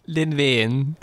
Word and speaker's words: Len Wein: Len Fein. – Len Wein.